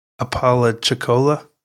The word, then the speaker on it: Apalachicola: Apalachicola. – Nice. – Oh, sorry. I should have let you mispronounce it first. no. but Apalachicola is a city on the southern coast of Florida between Tallahassee and Panama City.